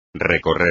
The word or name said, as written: recorrer